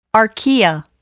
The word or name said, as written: archaea